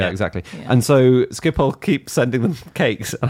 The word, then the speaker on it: Schiphol: My fact is that every time the UK delays a decision on expanding Heathrow, the head of Schiphol Airport in the Netherlands sends Heathrow a congratulatory cake to thank them for all the extra custom.